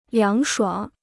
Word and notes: 凉爽: liáng shuǎng

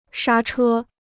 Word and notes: 刹车: shā chē

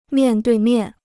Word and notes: 面对面: miàn duì miàn

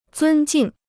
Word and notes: 尊敬: zūn jìng

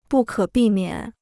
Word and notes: 不可避免: bù kě bì miǎn

